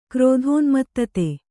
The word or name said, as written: ಕ್ರೋಧೋನ್ಮತ್ತತೆ